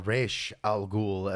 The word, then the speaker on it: Ra's al Ghul: Well, Ra's al Ghul, as we know, or Ra'sh al Ghul, as we pronounce it, means head of the demon, or the devil's head, or head of the ghoul.